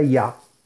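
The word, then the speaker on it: Ya (יה): La primera meditación es meditar en el nombre sagrado de Yud Hei, que se pronuncia Ya, y se usa en el Salmo 40 para librarnos de los espíritus malignos.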